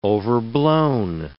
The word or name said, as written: overblown